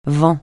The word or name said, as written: vent